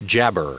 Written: jabber

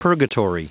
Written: purgatory

